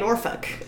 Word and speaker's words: Norfolk: As long as you don't say folk. – Okay. – Like, you can say Norfolk.